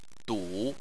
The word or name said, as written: du3